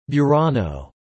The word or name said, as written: Burano